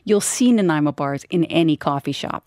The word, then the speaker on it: Nanaimo: It's Nanaimo bars.